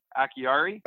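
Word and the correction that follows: Acciari: Oh, Akari. Noel Akari. – Akari. See? I knew I'd get it.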